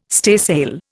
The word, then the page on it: staysail: Indian